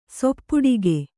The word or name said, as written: ಸೊಪ್ಪಡಗು